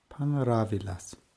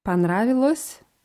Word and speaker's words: понравилось: Also brauchen wir wieder S-Weichheitszeichen und die Form wäre dann Panravilas. – Panravilas. Und diesmal mit A. Diesmal mit A. Die Aussprache ist fast gleich. Понравилось, panravilas.